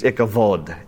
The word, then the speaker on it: Ichabod: Now circle in your Bibles there, Ichabod. In Hebrew, it's pronounced, the B is pronounced like a V. It's Ichavod. Ichavod means inglorious or without glory, because the Ark of the Covenant was a symbol of the nation of Israel.